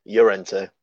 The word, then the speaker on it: Llorente: It should be pronounced Llorente because it's double L.